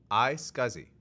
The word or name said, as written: iSCSI